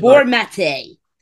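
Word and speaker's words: Gabor Maté: My pronunciation might not be right. Gabor Mate. – Yeah. – Have you heard of him? – Yeah. – It's like, Gabor Mate. – is that, yeah, you've got a better pronunciation of it.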